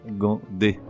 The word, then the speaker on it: dégingandé: Le mot s'écrit D-E-G-I-N-G-A-N-D-E. E. Et navré de te le dire, mais depuis quand la syllabe G-I-N se prononce-t-elle G-U-I-N Tu l'as deviné, le mot se prononce correctement gandé Résumé du comprimé numéro 87.